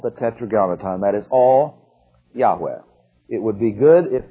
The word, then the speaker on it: Yahweh: And the most probable way it should be pronounced is to put in an A after the Y, Y-A-H, then W-E-H, which we come with Yahweh.